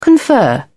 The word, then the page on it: confer: British English